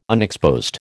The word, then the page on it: unexposed: American English